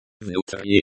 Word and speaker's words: Внутри: Внутри. Внутри. The stress is on the second syllable.